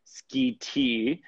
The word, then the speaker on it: Schiti: That's how you pronounce it, Schiti.